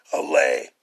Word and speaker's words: allay: People will do anything for those who encourage their dreams, justify their failures, ally their fears, allay. It's spelled A-L-L-A-Y. Allay their fears.